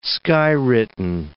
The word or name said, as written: skywritten